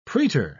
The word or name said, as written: praetor